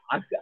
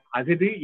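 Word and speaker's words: அஃது: நாம் அதை அக்கு அக்கு இக்கு என்று சொல்லும் போது அங்கு எதுகை மாறி போய்விடும், ஓசை மாறி போய்விடும். அதனால் அது அகுது இகுது என்பதுதான் சரியான உச்சரிப்பு என்பதற்கு இந்த பாடல் மிகச்சிறந்த எடுத்துக்காட்டாக.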